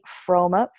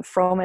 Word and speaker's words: Frome: Um, easiest, fastest way is on Instagram, and it's Melanie Frome, first and last name, pretty simple. Um, Frome is spelled F R O M E.